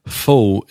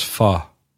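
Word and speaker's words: Phở: But I've since discovered that Fo is actually pronounced Fa.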